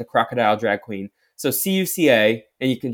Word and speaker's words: Cuca: I am spell it again. So C U C A, Cuca.